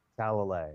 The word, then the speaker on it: Talalay: No, it's Talalay because it has the accent in what doesn't seem like the right place.